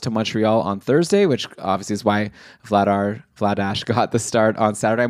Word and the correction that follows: Vladar: It's Vladar. – Vladar? – Well, I'm seeing, yeah, that's how I'm reading the pronunciation. – So it's like batter.